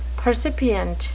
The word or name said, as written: percipient